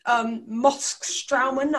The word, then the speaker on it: Moskstraumen: When you swam in the biggest maelstrom in the world, Moskstraumen, I think I'm saying that right. – Yeah, that's right.